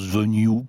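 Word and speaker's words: Venu: n'a autant de possibilités sportives que cette Venue. Ça s'écrit Venue et ça se prononce Venue, paraît-il, moi je veux bien.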